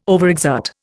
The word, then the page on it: overexert: Indian